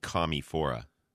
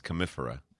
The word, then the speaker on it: Commiphora: So I mispronounced it. I was calling it Commiphora, but it's Commiphora.